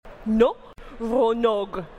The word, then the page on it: ŋo ronoɣ: /^ŋo ronoɣ/; Phyrexian